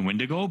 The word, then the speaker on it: Wendigo: Yeah, so the proper pronunciation is actually Wendigo, but some other cultures do refer as the Wendigo.